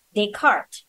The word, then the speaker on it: Descartes: And if you're using APA style, you will write an apostrophe S in the same way, unless the name ends in an unpronounced S, like Descartes.